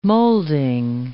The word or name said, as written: molding